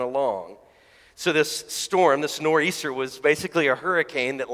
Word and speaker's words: Nor'easter: And in Acts 27, verse 14, it says this, but before long, a fierce wind called the Northeaster, if you're from New England, the Nor'easter, you don't pronounce the TH, rushed from the island.